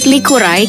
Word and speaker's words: likurai: The word likurai has two syllables, namely liku and rai.